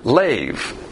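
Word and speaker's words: lev (לב): In the Hebrew, we have the word lev. That's how it's pronounced.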